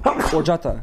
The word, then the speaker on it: horchata: No, you used the H. – I said horchata. – The H is silent. – Horchata.